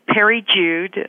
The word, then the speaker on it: Peri Jude: I'm at Peri Jude, and that's spelled P-E-R-I-J-U-D-E.